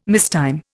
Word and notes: mistime: Indian

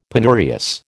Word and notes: penurious: American English

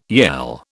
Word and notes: yell: British English

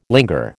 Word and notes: linger: American English

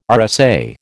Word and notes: RSA: American English

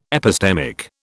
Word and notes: epistemic: British English

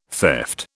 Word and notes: theft: British English

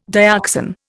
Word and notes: dioxan: Indian

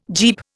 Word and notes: Jeep: Indian

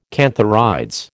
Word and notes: cantharides: American English

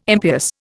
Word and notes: impious: Indian

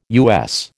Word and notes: US: American English

